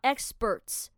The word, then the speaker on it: experts: 你看到 experts 这个字哈，它是中间是 p 嘛，对不对？但是这个 p 我们要把它念成 b 的发音，所以是 experts.